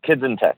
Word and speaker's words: Kids in Tech: Is that kids? Is that how it's pronounced? Kids Time Tex? – We like to confuse people. Yeah, it's kids in tech.